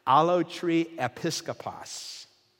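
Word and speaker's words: allotriepiskopos (ἀλλοτριεπίσκοπος): It's pronounced allotriepiskopos. And it is two words put together. It comes from allotrios, which means belonging to another, and episkopos, which means overseer or bishop.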